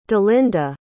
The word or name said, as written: dalinda